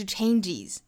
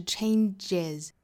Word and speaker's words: changes: 好，后面的这个 changes 也是要注意的三个点，一个是它的元音是一个滑动的 a, 再加后面的鼻音揉在一起发 ain, change 这个词后面呢加了 es, 也就是变成复数的话呢，加的这个元音要注意是一个松口的这个 a 的发音，那也就是相当于 sit, sit 里面的这个松元音 a, 不要读成了紧音的那个 e 的音哈，不是 changes, 应该是 changes。